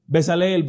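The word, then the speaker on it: Bezaleel: Estoy hablando de Besaleel o Besaleelí, como se dice. Muchos pronuncian la es ahí, en el hebreo casi no se pronuncia. Besaleel podremos decir.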